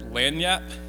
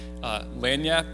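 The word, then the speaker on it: lagniappe: I don't know if anyone in here is from the area, but they have this word, lanyap. Okay, lanyap, it's spelled L-A-G.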